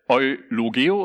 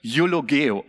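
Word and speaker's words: Eulogeo (εὐλογέω): Eulogeo, ja, hier, wenn man es so im Deutschen sieht, würde man vielleicht Eulogeo oder sowas sagen, aber im Griechischen wird es ungefähr Eulogeo ausgesprochen.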